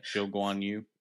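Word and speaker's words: Zhou Guanyu: And Sauber's had Bottas and Guan Yu Zhou, however you pronounce his name. – Zhou Guan Yu.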